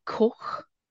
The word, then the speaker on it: Koch: Michael Cook, Robert Cook. Sorry, Robert. Robert it's well, it's like I think it's pronounced like Koch.